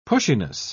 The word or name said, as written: pushiness